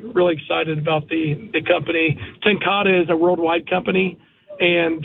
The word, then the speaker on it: Ten Kate: It's Tenkata. So it's spelled like Ten Kate. – Yeah. – But it's, yeah, Tenkata. – I'm learning too. I was learning too. I called it Tenkate, you know, and then I was at the trade show this past week in New Orleans, and I learned a lot about the product.